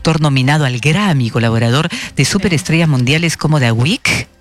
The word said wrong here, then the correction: The Weeknd: The Weeknd,